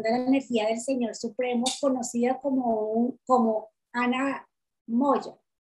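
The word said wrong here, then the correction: Annamaya: El Maya. – ¿Cómo se dice? Ana Maya. – Ana Maya.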